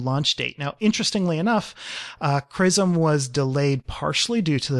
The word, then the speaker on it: XRISM: Then there's SRISM. How do we even pronounce this? XRISM. – CRISM. – CRISM. There we go.